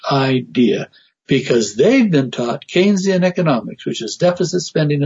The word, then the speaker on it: Keynesian: And I know Keynesian economics. I know where that came from, John Maynard Keynes. But I find it interesting that it rhymes with Keynes.